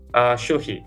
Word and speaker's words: Shulhi: Shulhi, yeah.